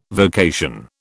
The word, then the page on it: vocation: British English